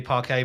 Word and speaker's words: parquet: Well, if it was parquet, it would have double T E on the end of it. So uh, yeah, so I think it's definitely parquet. But you know,